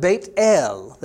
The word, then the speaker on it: Beit El: I am the God of Beit El. That's how you say it, b'Ivrit in Hebrew.